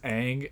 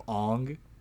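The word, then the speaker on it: Aang: As if they pronounce uh Ang as Ang instead of Ong, that's, that's a, a, good sign.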